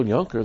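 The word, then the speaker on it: Junker: I know there's a Phil Yunker that owns a, what do you call it, people that grow grapes? – Vineyard. – There's a Phil Yunker spelled like my name with a J in South Africa that's got a vineyard.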